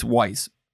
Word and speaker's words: Weiss: I think she calls it Weiss. Not Vice. I'm just used to the German pronunciation of that.